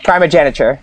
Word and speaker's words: primogeniture: Primogeniture.